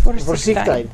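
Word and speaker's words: voorzichtigheid: The emphasis would be on the second syllable, so Vorsichtigkeit. – Vorsichtigkeit.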